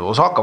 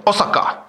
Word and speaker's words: Osaka: Osaka, vabandust, hääldasin valesti, jaapanlased korrigeerisid, mitte, mitte nii nagu eestlased, Osaka, vaid Osaka.